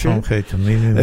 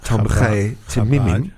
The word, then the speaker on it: Tomchei Temimim: Tomchei Temimim. – It's a yeshiva, Tomchei Temimim.